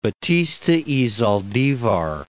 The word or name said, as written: Batista y Zaldivar